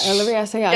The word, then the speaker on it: Olivier Assayas: I never know how to say his name. – Oh, yeah. Olivier Assayas. – Yes, mm-hmm.